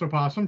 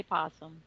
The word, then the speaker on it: opossum: If you wish to be wrong, it's pronounced opossum, sure. – Yeah. – Yeah. It's spelled opossum. – Yeah. – It is. – But we all just say possum.